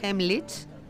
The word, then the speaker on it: Heimlich: Qué importante es saber, por ejemplo, hacer esa maniobra, que voy a ver si soy capaz de decir el nombre, Heimlich. ¿Lo he dicho bien, Raquel? – Sí.